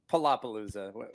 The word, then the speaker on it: Palapalooza: Palapalooza podcast. Did I say that right, or is it? – Palapalooza. – That's what I've heard. – We get a Palapalooza. Yeah, Palapalooza podcast.